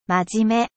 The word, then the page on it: sérieux: French